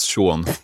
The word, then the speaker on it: Sean: fattar inte att det uttalades Sean.